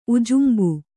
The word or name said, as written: ಉಜುಂಬು